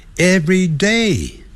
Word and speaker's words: every day: 但是 every 跟 day 分开的时候，要念成 every day，重音在 day 那个地方。